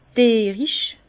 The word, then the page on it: dierisch: Bas-Rhin; Herrlisheim